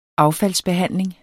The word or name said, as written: affaldsbehandling